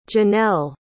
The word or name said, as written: jennelle